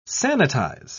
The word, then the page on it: sanitise: British English